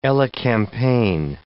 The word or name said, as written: elecampane